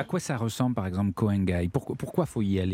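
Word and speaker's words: Koh Ngai: Vous avez l'île de Koh Nangai également qui est Koh Nangai, attention parce qu'on prend des notes.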